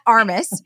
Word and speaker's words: Armas: but Kat Armas, how do you pronounce it correctly? – Yes, Kat Armas is great, but in Spanish it's Armas, so Kat Armas, but yes, Kat Armas is also great.